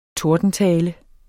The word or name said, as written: tordentale